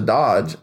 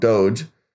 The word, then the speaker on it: Doge: And it's compared a lot to Doge. Or sorry, I always say that wrong. Doge.